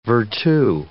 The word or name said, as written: vertu